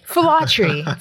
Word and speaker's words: Filatre: In English, we just say Filatre, which still people have a lot of difficulty with, even though we simplified it for them. But yeah. – You need a lesson to know how to pronounce that name. – Filatre. Filatre. So in order, my eldest sister is Audrey.